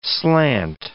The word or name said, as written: slant